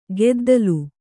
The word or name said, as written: ಕುದ್ದಲ